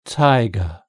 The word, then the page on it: tiger: British English